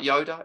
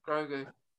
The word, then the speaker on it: Grogu: If I say it right, Yoda. It's not Yoda, though, is it? – Grogu. – Grogu.